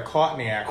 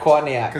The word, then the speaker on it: Courtney Act: You have to say it right. Courtney Act. – Courtney Act. – Because that's how you... – Courtney Act. – Caught in the act.